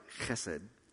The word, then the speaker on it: chesed: But if you say it right, chesed, then the person sitting in front of you will feel something wet on the back of their neck.